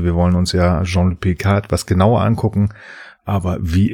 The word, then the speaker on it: Picard: Die Frage ist ja, würdest du ihn jetzt Picard aussprechen, also Englisch oder äh, Französisch, denn es ist nicht Picard, sondern Picard, weil dann ist das D stumm, meine ich.